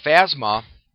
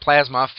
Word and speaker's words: Phasma: phasma not plasma phasma there i said it correctly